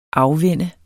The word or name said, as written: afvænne